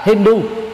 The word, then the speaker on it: Hindu: Người Ba Tư có thói quen không phát âm chữ H nếu nó là tiếp đầu ngữ, cho nên mặc dù viết là có chữ H là Hindu tương đương với Indus, chứ thực ra đó khi đọc đó, thì phải đọc H trở thành là H câm.